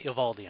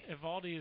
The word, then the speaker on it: Eovaldi: I'm not sure if I'm pronouncing his name right, but I think we all liked Nathan, the right-handed pitcher who went to the Marlins. – They say Eovaldi on TV. – Eovaldi is. I think you're right. They make that one syllable.